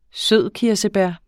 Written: sødkirsebær

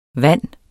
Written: vand

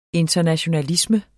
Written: internationalisme

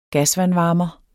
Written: gasvandvarmer

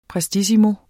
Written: prestissimo

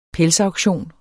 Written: pelsauktion

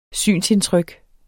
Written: synsindtryk

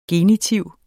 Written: genitiv

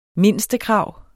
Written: mindstekrav

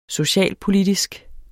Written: socialpolitisk

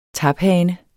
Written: taphane

